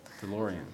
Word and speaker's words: DeLorean: So, but rather, we have the DeLorean Is that how you say it in English? – DeLorean, yeah.